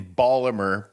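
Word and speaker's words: Baltimore: He was born in Ballimer. – The town. – That's how you say it.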